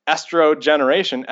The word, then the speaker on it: Estrogeneration: I mean, it's a tricky title for people to pronounce, but Estrogeneration, estrogen, can affect, and artificial estrogen affects future generations.